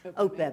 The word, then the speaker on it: OPEB: I think I heard you talk about the pension fund and the REB, whatever. – OPEB. – OPEB, that's mm-hmm. how you say it.